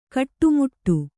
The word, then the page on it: ಕಟ್ಟುಮುಟ್ಟು: kaṭṭumuṭṭu